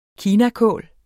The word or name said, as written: varietet